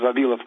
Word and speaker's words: Vavilov: The pronunciation is Vavilov.